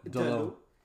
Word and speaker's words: Dalot: And you have Dalot. Did I pronounce that right? – Dalot.